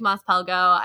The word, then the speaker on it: Mos Pelgo: Pelgo. You were going to say it wrong. I really like Mos Pelgo.